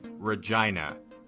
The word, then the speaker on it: Regina: It's pronounced Regina. – Sorry. – She's named after Saskatchewan.